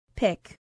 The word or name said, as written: pick